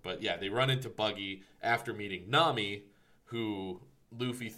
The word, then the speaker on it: Buggy: To be fair, it is a long U, and then we meet one of the next characters, Buggy, who's the next big antagonist, Buggy the Clown, and that is a short U.